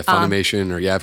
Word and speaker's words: Funimation: I'm more than happy to do audiobooks and anything else, but I've always wanted to work for Nickelodeon, Disney, Cartoon Network, hopefully I'm saying this correctly, Fun Animation. I keep saying it wrong for some bizarre reasoning, but... – Oh yeah, Funimation, I've heard different things. I'm totally wrong.